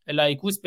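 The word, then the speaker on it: لایکوس (λαϊκός): زبان یونانی لایکوس با تلفظ لایکوس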